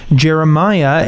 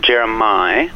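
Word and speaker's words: Jeremih: We'll continue the announcements of new music with some music from Jeremiah, and he worked with some pretty sizable names on this, if I might say. – He did indeed. It's actually pronounced Jeremiah. – Oh, Jeremiah.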